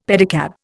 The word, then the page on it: pedicab: Indian